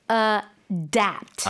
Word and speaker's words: adapt: You have to say adapt. – Adapt. – Yeah. – A-D-A-P-T. 스펠링도 다르죠. – Yeah. Adopt. Adapt.